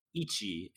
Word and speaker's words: ichi: They expect you to remember ground, one, and that ichi is how you pronounce it.